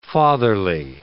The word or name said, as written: fatherly